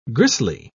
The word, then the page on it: gristly: British English